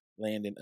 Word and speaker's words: Landon: Landon of Television Talented.